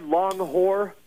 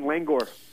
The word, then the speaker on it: Langor: If you're a Mexican prison guard, Long Whore. – Yeah. – If you're just a normal person, Langor.